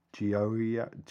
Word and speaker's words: Gioia: This is a nightmare of vowels, this word. It's spelled G-I-O-I-A. So I'm going to go with Gioia.